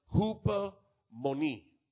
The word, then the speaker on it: hupomone: It's a Greek word that says, it's pronounced hupomone.